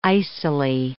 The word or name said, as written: icily